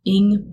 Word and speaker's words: Ng: I can be found on, I'm most active on Twitter, and my handle is at Janet Ng Bio.